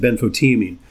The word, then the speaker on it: benfotiamine: You pronounce it benfotiamine.